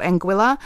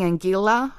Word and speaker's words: Anguilla: And I was saying Anguilla for ages. It's Anguilla.